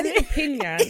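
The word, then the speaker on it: Estupiñán: Estupinyan. That's how you pronounce it.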